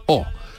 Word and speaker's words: eau: Por ejemplo, para decir agua se dice o pero se escribe EAU, que son tres letras que ninguna es la U.